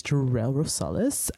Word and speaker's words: Jerell Rosales: His name is Jerell Rosales.